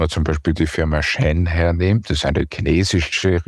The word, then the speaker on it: Shein: Ja, und auch wir haben uns mit dem Online-Modehändler Shein befasst, so wird er nämlich laut unserer Recherche ausgesprochen.